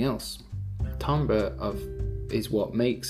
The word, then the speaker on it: timbre: Music often has timbre. I don't know if I'm really pronouncing it well. Oh, here we go. This is a French word pronounced the French way, timbre. Timbre. The timbre. It's the way that it's spelled right here is T-A-M-B-R.